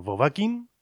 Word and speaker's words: Vovakin: Pues por sus apellidos. Aquí teníamos a Bartomiec Wozniak, el que pone la primera sílaba, Vo, a Mateusz Wachowiak, que pone la segunda sílaba, Va, y a Paula Kinaszewska, que pone la tercera sílaba, Kin, Vovakin. Ellos tocan respectivamente percusiones,